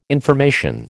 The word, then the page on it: information: American English